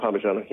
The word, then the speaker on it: parmigiana: Eggplant parmigiana. Am I saying it right? – Yeah, eggplant parmigiana.